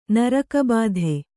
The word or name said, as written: ನರಕಬಾಧೆ